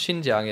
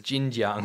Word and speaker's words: Xinjiang: Legitimate articles get downvoted into oblivion, and people real proper comments get downvoted or removed, Gabriel Keane says, people that pronounce Xinjiang as Jinjiang really make me irritate.